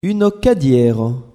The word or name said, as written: uno cadiero